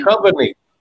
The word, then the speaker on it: Duchovny: How do you say his last name? David of X-Files. David Duchovny. – Duchovny. – Duchovny. Yeah, right there.